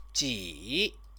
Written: ji

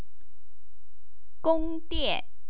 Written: gongdian

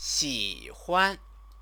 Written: xihuan

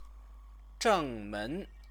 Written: zhengmen

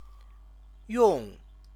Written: yong

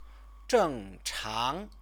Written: zhengchang

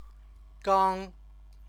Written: gang

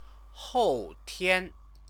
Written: houtian

